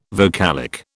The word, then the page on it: vocalic: British English